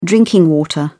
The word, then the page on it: drinking water: British English